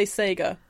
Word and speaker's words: Sega: I think you'll find that is an American pronunciation of Sega. – Sega. Keza, back me up here. – The Japanese would say Sega. Therefore, right, that's what it must be.